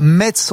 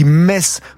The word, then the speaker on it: Metz: On ne dit pas Metz, on oublie le T, on dit Metz comme les Messins, parce qu'on est nombreux, moi aussi d'ailleurs, à faire l'erreur.